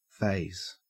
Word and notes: faze: Australian English

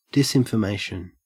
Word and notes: disinformation: Australian English

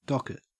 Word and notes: docket: Australian English

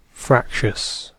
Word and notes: fractious: British English

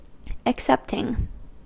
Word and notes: excepting: American English

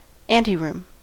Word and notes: anteroom: American English

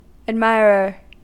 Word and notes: admirer: American English

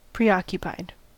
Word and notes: preoccupied: American English

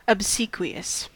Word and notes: obsequious: American English

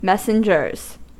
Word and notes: messengers: American English

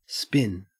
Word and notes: spin: Australian English

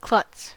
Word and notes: klutz: American English

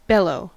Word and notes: bellow: American English

